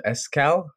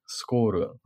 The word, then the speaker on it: Escal: On my end, I have this is called Escal, or how do you pronounce it in Japanese? It's like Skoru, right? – Yeah. – But on the label, it's like there's also written in Romanized letters, S-K-A-L.